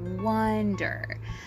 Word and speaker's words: wonder: And the second word is wonder, wonder. So these two pronunciations, people often mistake wander, wonder. They definitely have different sounds. Wonder is spelled W-O-N-D-E-R.